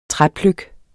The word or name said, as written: træpløk